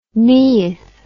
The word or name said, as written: Neith